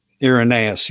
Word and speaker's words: Irenaeus: And I can't even give him exactly the credit, because he's only quoting Irenaeus, or Irenaeus. How do you say it? That's the right way to say that. – Irenaeus, yeah.